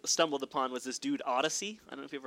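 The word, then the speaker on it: Oddisee: Odyssey. – But it's pronounced, like, the way it's spelled out is Odd I C, like S-E-E.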